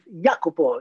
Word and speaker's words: Jacopo: Just a reference, my name can be pronounced, you're from Germany, and you can pronounce it very easily, Jacopo. The J is Ja.